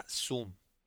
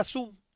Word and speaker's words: Xoom: El pasado jueves la compañía Motorola anunció su nueva tableta Zoom, que by the way se escribe X-O-O-M.